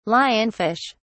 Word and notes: lionfish: British English

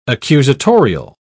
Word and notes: accusatorial: British English

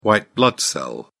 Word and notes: white blood cell: British English

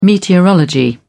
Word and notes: meteorology: British English

